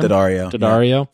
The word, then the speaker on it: Daddario: So Alexandra Daddario, is that how you say her name? – Daddario.